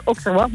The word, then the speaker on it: Auxerrois: Auxerrois? – Is altijd lastig. Het naam is Auxerrois. Moet je een beetje denken aan de stad Auxerre natuurlijk.